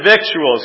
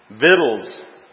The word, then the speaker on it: victuals: Joshua 9:14 says, and the men took their, I said victuals. Who knows how to pronounce that? V-I-C-T-U-A-L-S. Okay, that's what I would have said too. Somebody corrected me. It is pronounced V-I-D-L-S. Vittles.